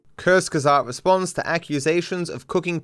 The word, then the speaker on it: Kurzgesagt: How Kurzgesagt, saying it correctly now, at least closer than I was before, cooks propaganda for billionaires.